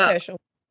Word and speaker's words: Herschel: I think it's pronounced Herschel. – Yeah, it's Herschel.